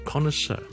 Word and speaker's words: connoisseur: 咁啊，当然小叔讲嘅发音咧就系英文嘅发音，Connoisseur。你可以当有两个重音，第一个重音 con，然后第二个重音是 sir，做 connoisseur 就是一个非常识食的人。